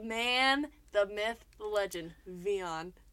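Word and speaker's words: Wiaan: Vion De Beer.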